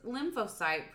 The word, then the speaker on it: lymphocyte: And she says, his lymphocyte percentage is pretty high.